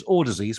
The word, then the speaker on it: Audeze: It's not, so it's pronounced more or less like Audacy, but it's Audacy, spelled A-U-D-E-Z-E, as in Aud-easy, Audacy is how they pronounce it.